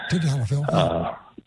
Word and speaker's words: Tadalafil: Tadalafil, however you pronounce that. – Tadalafil.